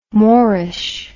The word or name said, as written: moreish